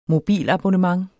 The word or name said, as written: mobilabonnement